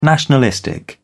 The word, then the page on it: nationalistic: British English